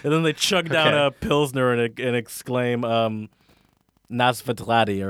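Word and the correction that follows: Na zdraví: or how do you say, is that how you say cheers? – Nasdravi. – Oh, Nasdravi. Nasdravi, bitch.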